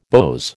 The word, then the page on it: beaux: American English